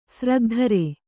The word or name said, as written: ಸ್ರಗ್ಧರೆ